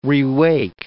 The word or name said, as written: rewake